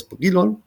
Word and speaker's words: Brilon: Also 43 Jahre alt, aus Brilon. Also spricht man nicht Französisch aus.